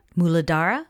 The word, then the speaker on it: Muladhara: And so I identified, I know a lot of people identify it with the root chakra, the Mulatuhadra. – The chakra? Muladhara? – Muladhara. Hmm.